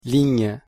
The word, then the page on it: ligne: French